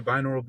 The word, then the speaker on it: binaural: Yeah, they're binaural beats.